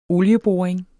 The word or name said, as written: olieboring